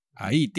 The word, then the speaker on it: ahí: Ahí, tío. – Allí. – Now, there is also ahí. The H is never pronounced.